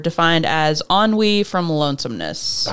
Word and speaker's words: ennui: I always want to pronounce it NUI, and yeah. I'm like, that's not what that is.